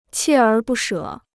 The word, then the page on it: 锲而不舍: qiè ér bù shě